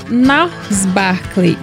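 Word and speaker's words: Gnarls Barkley: justamente na abertura de Nars Barkley. Deve ser assim que se pronuncia, mas enfim. Do Crazy. – Certinho, não. É, certinho.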